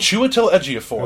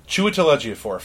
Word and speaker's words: Chiwetel Ejiofor: And then, of course, we also have Baron Mordo, which you're going to have to pronounce this guy's name, because Chiwetel Ejiofor. – Okay. – Chiwetel Ejiofor.